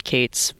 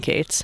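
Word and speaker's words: Kaats: So it was pronounced Cates for many generations prior to me saying Cates.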